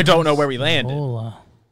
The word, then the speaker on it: Osceola: I don't know where Oscala, Osceola. How do you pronounce this town? It's a county in Florida. – Osceola, yeah.